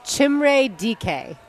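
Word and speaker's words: Chimere Dike: By the way, you pronounce it Chimre DK.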